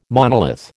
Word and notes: monolith: American English

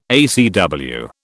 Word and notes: ACW: British English

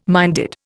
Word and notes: minded: Indian

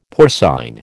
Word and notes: porcine: American English

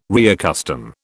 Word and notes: reaccustom: British English

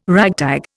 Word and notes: ragtag: Indian

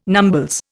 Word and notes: numbles: Indian

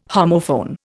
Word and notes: homophone: Indian

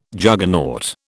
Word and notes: Juggernaut: British English

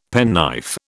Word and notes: penknife: British English

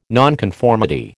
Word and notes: nonconformity: American English

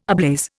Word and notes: ablaze: Indian

